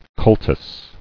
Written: Cultuses

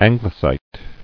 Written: Anglesite

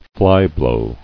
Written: Flyblow

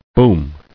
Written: Booming

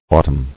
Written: Autumnal

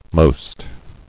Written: Most

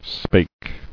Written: Spake

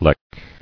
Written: Lech

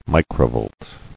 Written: Microvolt

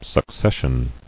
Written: Successional

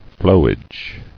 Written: Flowage

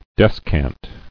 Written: Descant

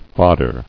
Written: Foddering

